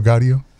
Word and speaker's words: Gaudio: Hey, it's Dino Gatto. Dino Gaudio. – Just get his name right.